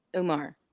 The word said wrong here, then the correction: Amar: I'm saying it wrong. – Yeah, Amar. – So bad. – Amar. Yeah. – Okay, got you. Amar, please tell us where they can find you.